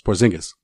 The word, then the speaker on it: Porzingis: How am I saying it wrong? – It's Porzingis.